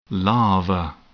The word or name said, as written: larva